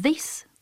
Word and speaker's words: this: Sí, mira, por ejemplo, this, tal como lo he pronunciado, es una pronunciación de una I corta, es un pronombre demostrativo que indica proximidad.